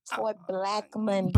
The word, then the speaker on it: Tory Beckmond: I'm here for it, but I need you to pronounce it right. It's Tory Beckmond.